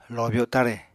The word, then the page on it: lobyo tare: Apatani